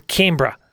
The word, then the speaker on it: Canberra: In Australia, remember, we shorten everything. – Yeah. – Mm-hmm. So Melbourne is Melbourne. Canberra is Canberra.